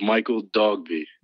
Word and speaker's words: Michael Dogbe: No, I appreciate you having me, but it's pronounced Michael Dogbe. – Dogbe. I was close. – Yeah, it's close. Just like it looks.